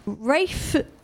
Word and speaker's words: Ralph: Rafe, it's spelt Ralph, so I'm counting him as a Ralph.